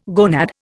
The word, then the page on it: gonad: Indian